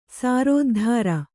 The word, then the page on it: ಸಾರೋದ್ಧಾರ: sārōddhāra